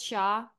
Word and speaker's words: Hsia: And then my last name, it's HSIA. You pronounce it as Sha.